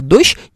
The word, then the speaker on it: дождь: Объясните а особенности, опять же, фонетические просто правила, а, предполагающие, что вы должны произносить слово «дождь» именно так. Там «щ» на конце. Не «дождь», а «щ» дождь.